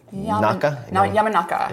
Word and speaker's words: Yamanaka: Yamanaka.